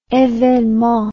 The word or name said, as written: evenement